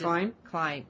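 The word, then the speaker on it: Khlyen: I always pronounced it that way too, Sean, until they started pronouncing his name as Klein on the show. So I have to remember when I'm typing up the recap that it's Klein, not Kylan.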